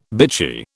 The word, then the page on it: bitchy: British English